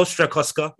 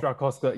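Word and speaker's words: Strakosha: I may have butchered his name. – Well done. You did it well, Strakoska. Yep, you done well. – Strakoska, yeah.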